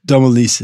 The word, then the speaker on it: Domilise's: What are your thoughts on domiciles? Or even how to pronounce it right? Uh, Domilise's. Domilise's.